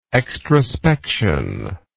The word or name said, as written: extrospective